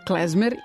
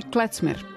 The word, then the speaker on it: klezmer: Verovatno da na nemačkom govornom području bi govorili klecmer, ali s obzirom da je reč hebrejskog porekla, onda sam ipak dao prednost izgovoru, koji je, čini mi se, čak i procentualno više zastupljen u svetu nego klecmer. Uglavnom govore klezmer.